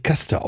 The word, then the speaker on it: Caste: Absolut eindringlich beschrieben und meine Buchempfehlung zum Thema Unterdrückung sowie Rassismus, das Buch Caste oder Caste, wie wird es ausgesprochen? – Ich würde das tatsächlich Caste aussprechen. Weil Caste, okay. – Ist zwar mit C geschrieben, aber ich glaube, dann versteht man es besser, auch direkt, was gemeint ist.